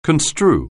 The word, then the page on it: construe: anglais